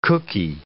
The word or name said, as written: cooky